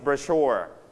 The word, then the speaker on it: brochure: ใครจำได้ว่าสิ่งนี้นะครับออกเสียงว่าไงในภาษาอังกฤษ brochure ไหมเราออกเสียงเน้นที่ you stress on first or second syllable. Second syllable. So you pronounce it brochure. Okay, brochure. But in British we pronounce brochure. Okay, you can pronounce it like. Brochure or brochure, it's okay, okay?